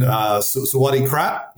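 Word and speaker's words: Sawadee Krap (สวัสดีครับ): So to our friends in Thailand, Sawadee Krap, did I say that correctly? – Yeah, that's correct.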